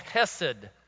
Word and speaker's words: chesed: Now, in the Hebrew world, there's a word for this loving, loyal kindness, and it's called chesed. You might spell it H-E-S-E-D.